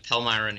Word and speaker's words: Palmyra: So, um, you're in Pal- Palmyra. Is that how you say it? – Yeah, Palmyra, New York.